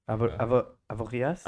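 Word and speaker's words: Avoriaz: Avorias? – Avoria. Yeah. The Z is silent.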